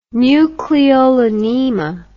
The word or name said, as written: nucleolonema